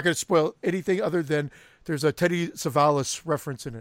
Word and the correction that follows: Telly Savalas: Telly Savalas.